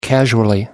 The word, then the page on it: casually: American English